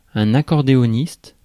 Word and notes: accordéoniste: France; [œ̃.n‿a.kɔʁ.deɔ.nist]

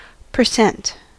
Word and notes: percent: American English; /pə(ɹ)ˈsɛnt/

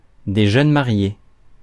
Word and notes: jeunes mariés: /ʒœn ma.ʁje/

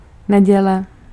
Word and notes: Sunday: /ˈsʌn.deɪ/

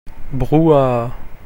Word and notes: hoo-ha: /ˈhuːˌhɑː/

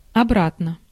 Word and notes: back: /bæk/

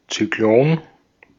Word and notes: Zyklon: /ʦy.ˈkloːn/

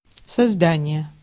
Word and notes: создание: /sɐˈzdanʲɪjə/

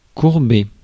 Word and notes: courber: [kuʁ.be]; France

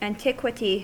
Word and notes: antiquity: American English; /ænˈtɪk.kwʌ.ti/

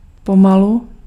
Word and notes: sakta: /ˈsakˌta/

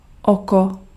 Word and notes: oko: [ɔkɔ]

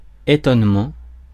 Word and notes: étonnement: /e.tɔn.mɑ̃/

